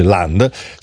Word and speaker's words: Land: Allora, il giorno dopo questo risultato elettorale nel Land, perché viene a pronunciare all'inglese, ma si dice Land,